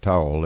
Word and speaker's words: Towle: Now, his name is pronounced like Towle, that you dry yourself off with, but it's spelled T-O-W-L-E, if you want to Google this guy up.